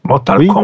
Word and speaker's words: Mortal Kombat: Par contre, tu prononces toujours le T à la fin de Mortal Kombat. – Oui, parce que c'est un jeu anglais. – Mortal Kombat.